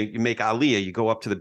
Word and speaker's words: aliyah: So what Sheila, Alan, any of you, if you want to explain, tell me how to say it properly when you're making aliyah, and then You're saying it right. Aliyah.